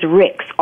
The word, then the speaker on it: Ricks: And my last name is Ricks, RICKS.